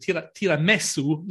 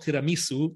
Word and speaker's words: tiramisu: And he created this thing called tiramisu, not tiramisu, because he spoke Venetian dialect, and that's how you say it in Venetian dialect.